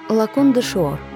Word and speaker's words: Лакон де Шуор: Меня зовут Лакон де Шуор. Ударение на втором слоге.